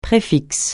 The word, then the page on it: préfixe: francais